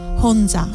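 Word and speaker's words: Honja (혼자): Honja.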